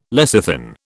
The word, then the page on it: lecithin: British English